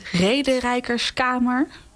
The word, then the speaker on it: Rederijkerskamer: Rederijkerskamer. – Ja, het is een heel... – Zeg ik het goed? – Ja, dat zeg je heel goed.